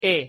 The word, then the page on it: e: Spanish